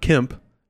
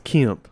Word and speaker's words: Kemp: You know, that's drawn out elongated vowel sound, right? – Yeah, like it's not Kemp, it's Kemp. – Kemp. – Right? Like, that's how you say it.